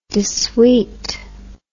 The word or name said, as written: desuete